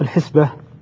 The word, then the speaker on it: الحسبة: أن الحسبة تنطق الحسبة بكسر الحاء.